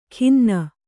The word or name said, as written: ಖಿನ್ನ